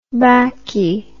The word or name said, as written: backie